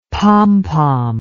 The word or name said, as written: pompom